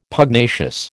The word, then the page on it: Pugnacious: American English